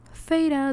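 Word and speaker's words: Feyre: Todo empieza cuando nuestra protagonista femenina, Feira, que por cierto se escribe Feira, pero se pronuncia Feira, una chica de 19 años se encuentra en medio del bosque congelado cazando.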